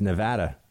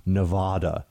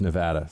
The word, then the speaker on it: Nevada: Turns out, yeah, it's Nevada. It is not Nevada. A lot of New Yorkers say Nevada, though, I will have you know. But it is Nevada.